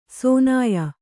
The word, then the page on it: ಸೋನಾಯ: sōnāya